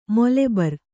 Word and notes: ಮೊಲೆಬರ್: Kannada

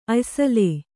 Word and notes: ಅಯ್ಸಲೇ: Kannada

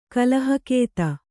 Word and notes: ಕಲಹಗತಿ: kalahagati

